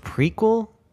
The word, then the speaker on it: Prequelle: Then you have, I guess it's pronounced prequel. It's not spelled like prequel. It's prequelle. Prequel by Ghost.